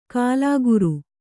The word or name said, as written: ಕೂಲಿಕಾರ